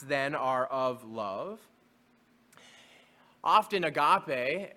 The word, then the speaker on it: agape: You can spell it A-G-A-P-E, agape.